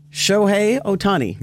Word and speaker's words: Shohei Ohtani: His teammate, Shohei Ohtani.